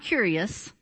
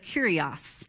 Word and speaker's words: Kurios: Not curious. That's what I've called it for in a month. But Kurios. And that's the Greek word.